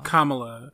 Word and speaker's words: Kamala: Oh, did I say her name right? I said it right. Kam- Kamala. – Kamala.